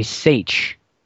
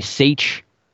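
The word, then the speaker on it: seiche: Many times in lakes such as Okanagan and Loch Ness, there are events called a seitch. And a seitch, if you want to look it up, you don't believe me, you can look it up.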